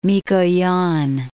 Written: Mikoyan

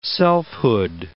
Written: selfhood

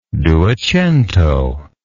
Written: duecento

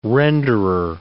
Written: renderer

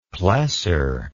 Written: plessor

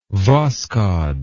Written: Voskhod

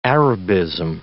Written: Arabism

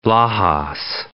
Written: Lajas

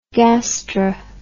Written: gastr

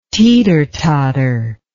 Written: teeter totter

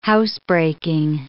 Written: housebreaking

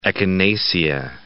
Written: echinacea

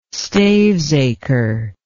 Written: stavesacre

